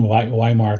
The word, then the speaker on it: Weimar: But he was also admired by the entire circle of philosophers associated with what's called Weimar, I'll pronounce it the German version, Weimar classicism, which basically gave birth to the German Romantic movement.